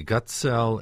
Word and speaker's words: Gutgesell: The second G is silent. – The Gutgesell Endowed Professor in the Department of Communication at the University of Illinois at Urbana-Champaign.